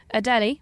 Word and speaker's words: Adélie: Adélie. – The Adèle penguin. – It's spelled A-D-E with an apostrophe L-I-E. – Adélie. All I hear is Adèle.